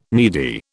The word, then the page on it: needy: British English